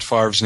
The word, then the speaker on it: Favre: But you know, nobody could pronounce Brett Favre's name either, so.